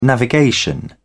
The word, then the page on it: navigation: British English